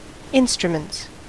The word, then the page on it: instruments: American English